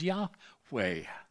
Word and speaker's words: Yahweh: L-O-R-D in verse 8 is all in capital letters. It translates the Hebrew term Y-H-W-H. We pronounce as Yahweh.